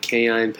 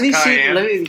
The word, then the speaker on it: cayenne: Is that the way you pronounce cayenne? – Cayenne. Is that what it's called? Canine? – It's cayenne.